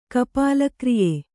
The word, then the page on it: ಕಪಾಲಕ್ರಿಯೆ: kannada